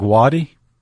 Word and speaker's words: Watie: how do you pronounce that last name? – I think Wadi. – W-A-T-I-E.